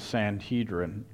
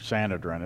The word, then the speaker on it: Sanhedrin: This group of people here was part of the Sanhedrin, or Sanhedrin, it's pronounced each way.